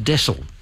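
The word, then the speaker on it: deasil: And someone got in touch with me, and they'd come across this quote that mentioned widdershins, and it's pronounced desil. It's D-E-A-S-I-L, but it's pronounced desil, and it means clockwise.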